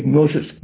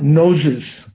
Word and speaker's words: gnosis: G-N-O-S-I-S. Geshe would say, I say gnosis, not noses.